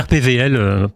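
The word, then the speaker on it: RPWL: Ça s'écrit W, mais on dit RPVL. – Ah oui, parce qu'en allemand, voilà. Le W, on ne le prononce pas, voilà.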